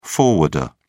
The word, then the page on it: forwarder: British English